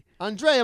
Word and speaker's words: Andrea: Yeah, like what's his first name? Andrea. – Like a lady. – Andrea Bocelli.